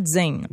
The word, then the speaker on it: Jain: J-A-I-N. – Mais en fait, on le prononce vraiment Jane.